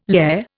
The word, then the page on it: lehr: Indian